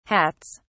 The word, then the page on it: hats: British English